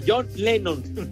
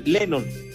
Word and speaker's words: Lennon: Lo importante es el legado que dejó Juan Lennon no, John Lennon. Lennon, así, pronúncialo. Lennon.